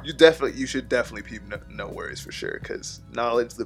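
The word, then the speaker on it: NxWorries: NX Worries. – Oh, yeah. No Worries.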